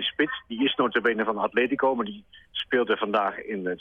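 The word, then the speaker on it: Atlético: Atletico, zeg ik dat goed? – Heel goed. Atleti.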